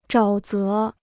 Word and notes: 沼泽: zhǎo zé

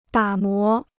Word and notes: 打磨: dǎ mó

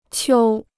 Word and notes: 秋: qiū

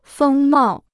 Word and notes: 风貌: fēng mào

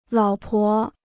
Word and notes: 老婆: lǎo pó

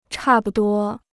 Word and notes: 差不多: chà bu duō

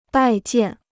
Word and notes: 拜见: bài jiàn

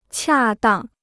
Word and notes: 恰当: qià dàng

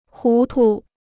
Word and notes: 糊涂: hú tu

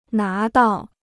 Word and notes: 拿到: ná dào

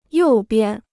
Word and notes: 右边: yòu bian

